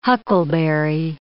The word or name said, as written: huckleberry